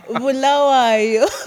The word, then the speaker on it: Bulawayo: I was born and bred in a city called Bulawayo, and it's in the southwestern part of Zimbabwe. – Bulawayo. – Bulawayo. – Did I say that correctly? – Bu. – Bu. – Bu. – Bu. – It's Bulawayo.